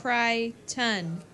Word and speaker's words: Crichton: Crichton.